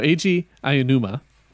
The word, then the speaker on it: Eiji Aonuma: E-I-J-I. – E-I-J-I-A-G. – Okay, so Eiji Aonuma.